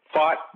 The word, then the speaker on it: Pfaadt: Tonight, probably the biggest mismatch here of day one of the four games, Corbin Burnes facing Brandon Pfaadt. It's not Fett, it's Pfaadt. Mm-hmm. That's the way it's pronounced.